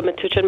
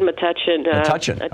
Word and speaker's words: Metuchen: Metuchen. – Metuchen, okay.